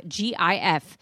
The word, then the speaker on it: GIF: It is the soft G pronounced GIF.